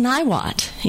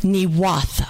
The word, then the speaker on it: Niwot: But it's not pronounced Niwot in Arapaho. It's pronounced, I say something like Niwatha.